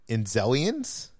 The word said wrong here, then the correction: Anzellans: Is that how you say it? – The Anzellans. Yeah. – Anzellans.